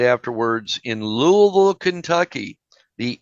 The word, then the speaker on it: Louisville: By the way, that's how you say it, folks. Louisville.